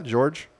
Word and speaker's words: Georges: Also on this date, 1910, modern neon lighting was first demonstrated by Georges. How do you say that? George? Just say George.